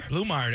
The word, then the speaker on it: Blumeyer: Is it pronounced Blumeyer? – Blumeyer, that's how you do it. That's like, how you say it. – Kind of like Brockmeyer? – Yeah, kind of. Blumeyer.